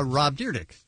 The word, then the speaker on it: Rob Dyrdek: Rob Dyrdek.